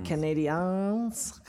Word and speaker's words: Canadiens: Canadiens.